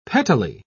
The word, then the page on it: pettily: British English